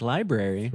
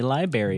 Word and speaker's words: library: That sneaky R. It's like library. – Throws me off. – All the Southern people I know say library.